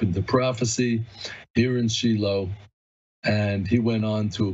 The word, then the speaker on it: Shiloh: This is where I live, in Shiloh, and we call it in Hebrew Shiloh, that's the actual Hebrew pronunciation.